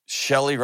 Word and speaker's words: Shelly: Shelly rhymes with jelly, but we won't even go there.